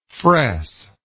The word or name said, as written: fress